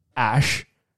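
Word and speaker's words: Æ: and then the AE is like pronounced ash, and then XASH.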